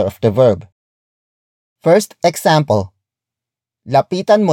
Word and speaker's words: lapitan: Word for word it's lapitan, mo, na, siya.